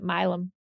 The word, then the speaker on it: Milam: We have a street in Houston called Milam, even though it's pretty much spelled like Milan with the M at the end instead of an N, but we pronounce it Milam.